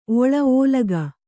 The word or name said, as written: ಒಳ ಓಲಗ